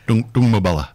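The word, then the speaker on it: Doongmabulla: So these things are, and that's putting at risk things like the, I won't say this right, Doomabulla Springs. Anyway, there's springs. – Oh, Doomabulla. – That's it. Thank you. Yes, I knew I wouldn't get the pronunciation right.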